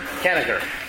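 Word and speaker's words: Kanigher: I was on a panel down here at Comic-Con with Robert, is it pronounced Kaniger? – Kaniger.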